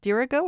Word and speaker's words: Dirigo: Accent on the antepenult. – Yes. – What does dirigo that mean? – So that means the accent is not on the last syllable. It's not on the syllable before the last syllable. It's on the syllable before that.